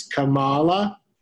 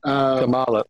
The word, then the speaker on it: Kamala: It's Kamala. – Right. – Yeah, I'm not sure. – So I only mispronounced it here. – Kamala, like koala.